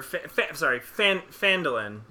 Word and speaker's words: Phandalin: And you get to Falindrin. Or Phandalin. You get to Phandalin.